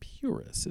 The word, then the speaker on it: PVRIS: And let's see, before that was the band Puris. It's spelled P-V-R-I-S with Goddess, which is a rockin' track off the Goddess EP.